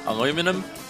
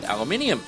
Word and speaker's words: aluminium: Now, if you're in America listening to this, you'll probably pronounce this next song aluminum. But if you're here in Australia, you'd pronounce it aluminium.